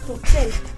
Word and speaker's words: Bruxelles: Bruxelles. – Ça s'écrit avec un X, mais ça se prononce Bruxelles.